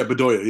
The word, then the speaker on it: Bedoya: That guy, Bedoya. Is that how you say it? – Okay. Yeah, Bedoya.